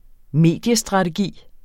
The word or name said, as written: Mediestrategi